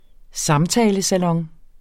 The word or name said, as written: samtalesalon